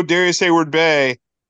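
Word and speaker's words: Darius Heyward-Bey: Although Darius Hayward Bay didn't kill anyone. – That's right. Yeah, there's the right way to say it.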